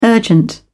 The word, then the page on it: urgent: British English